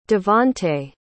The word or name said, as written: Devante